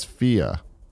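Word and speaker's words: Fe: Lord of Pwn says that F-E is pronounced as Fia.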